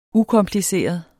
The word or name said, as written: ukompliceret